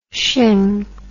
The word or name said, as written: sheng